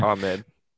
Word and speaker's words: Ahmed: Ahmed.